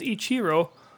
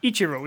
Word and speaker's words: Ichiro: It's funny because I believe the proper Japanese pronunciation is Ichiro, but yes. – Here over in the States, we change it to Ichiro. We put the accent on the first syllable.